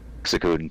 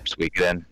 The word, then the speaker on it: Suikoden: Suikoden 2. – Oh, okay. – Suikoden. Yeah, sorry. Everybody pronounces it different.